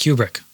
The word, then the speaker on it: Kubrick: Probably pronounced Kubrick, actually, because it's Stanley Kubrick's son.